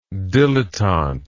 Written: dilettanteish

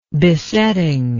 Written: besetting

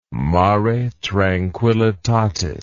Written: mare tranquillitatis

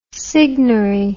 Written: signary

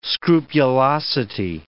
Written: scrupulosity

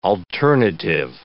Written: alternative

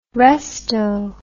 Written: resto

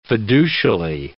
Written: fiducially

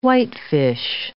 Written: whitefish

